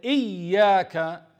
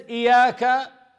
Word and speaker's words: إياك: They don't say إياك, that is شدة, stress on the ya, إياك نعبد. If he says Iyaka, that's different meaning. Iya in Arabic, it's the light of the sun.